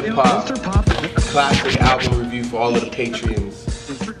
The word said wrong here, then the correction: patrons: Did I say it right? – Patrons. No, patrons. Fuck! – Patrons! – Man, I see the E in my head, man.